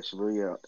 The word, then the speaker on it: Shibuya: They literally change, what is it, Shibuya? Is that how you pronounce it? – Yeah, the Shibuya.